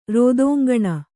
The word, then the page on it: ರೋದೋಂಗಣ: Kannada